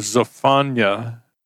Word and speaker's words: Zephaniah: Our Old Testament passage lesson today comes from the book of Zephaniah, or I guess the proper pronunciation is Zephaniah. Listen to a video by a Jewish scholar. That's how he pronounced it.